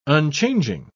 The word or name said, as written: unchanging